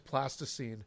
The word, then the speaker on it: plasticine: I kept calling it plaster scene, and Eric did enunciate, and I think he was trying to get back and going, I was pronouncing it incorrectly, because it is plasticine.